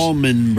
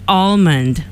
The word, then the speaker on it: Allman: Allman Brothers. – It says A-L-L-M-A-N, Allman. – It's A-L. – No, it's A-L-L-M. – Yeah, M-A-N, yeah, Allman. – Yeah, but you say it like almond.